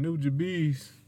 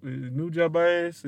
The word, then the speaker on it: Nujabes: Rest in peace to is it Nujabes or Nujabes? – Man, Nujabes. – I never got it right. – That's how I pronounce it. Nujabes.